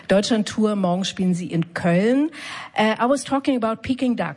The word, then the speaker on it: Köln: Did I say that right? – Köln. Yeah. Köln.